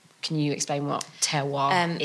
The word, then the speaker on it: terroir: I'm probably not pronouncing it right. Is it terroir? – Yeah, terroir.